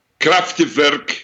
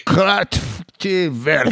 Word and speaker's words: Kraftwerk: Kraftwerk. Kraftwerk.